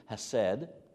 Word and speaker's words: hesed: The Hebrew word is the Hebrew word hased, and you would spell that in English, H-E-S-E-D, hased.